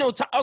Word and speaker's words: Otama: I met, I want to say her name is Otana, right? Otama? – Oh, so you just mean Otama.